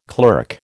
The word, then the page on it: clerk: American English